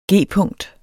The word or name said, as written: g-punkt